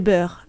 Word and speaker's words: Boers: Autant vous dire qu'avec les beurs, les, boers, les boers, non les Beurs, les Beurs, oui, on prononce comme ça les Beurs,